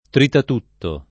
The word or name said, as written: tritatutto